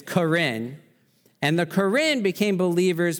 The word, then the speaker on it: Karen: Karen. It's spelled Karen, but it's not Karen.